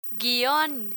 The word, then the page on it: guión: Spanish